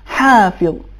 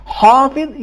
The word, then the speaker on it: حافظ: لہذا صحیح تلفظ یہ ہے حافظ. ان کل نفس لما علیہا حافظ. حافظ یہ تفخیم ہو گئی, اس میں تفخیم نہیں ہونی چاہیے.